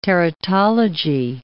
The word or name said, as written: teratology